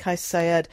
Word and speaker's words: Saied: So this new president, Kais Saied, is that how you say his name? – Yep, Saied.